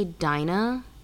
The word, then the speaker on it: Dinah: Every time I think about her, her name is Dina, but it's spelled D-I-N-A-H. So I always want to say Dinah. Because for me, Dina is spelled D-I-N-A.